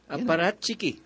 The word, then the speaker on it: apparatchiki: Ruthless, how do you pronounce this? Apparatchiki. – Apparatchiki.